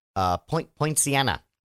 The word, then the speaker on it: Poinciana: Poinciana. I hope I'm pronouncing that correctly. Poinciana. – Yeah, like the Christmas flower.